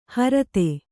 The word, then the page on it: ಹರತೆ: harate